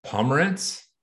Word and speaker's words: Pomerantz: We're super stoked to be here with our friends, Ben Gould and corporate bro, Mr. Ross Pomerantz.